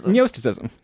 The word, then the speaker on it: Gnosticism: You pronounce it like gnocchi. – Gnosticism.